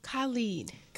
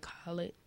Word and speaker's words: Khalid: Khalid. – And I was saying it wrong at first. I was like, Khalid.